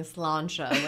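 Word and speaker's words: Sláinte: I'm super impressed with the way you're saying Solancha, which is the other most mispronounced word in Baltimore because it's spelled like slaint. – Right. – S-L-A-I-N-T-E. – Right. Yeah. – So Solancha. It takes years to know how to say it.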